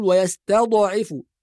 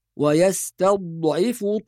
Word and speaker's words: ويستضعف: وكذلك ذكرنا قبل ذلك أن حرف الضاد الساكن لا يقلقل، فلا نقول ويستضعف، ولا ينطق ضاء، بعض الناس يقول يستضعف، هذا أيضا خطأ، نحافظ على الضاد الصحيحة من المخرج الصحيح، ويستضعف طائفة منهم.